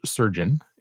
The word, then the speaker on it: SRGN: I've got a tool from Alex Povel called SRGN, pronounced surgeon.